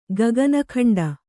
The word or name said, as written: ಗಗನಖಂಡ